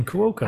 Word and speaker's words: Cuoco: I'm saying you're pronouncing as the letters that are on a screen. Cuoco. – I know. I know. – You said it. As it's written.